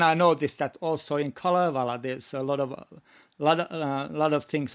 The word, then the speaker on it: Kalevala: The Kalevala, is that how you pronounce it in Finland? – Yes, that's the Kalevala. Yes.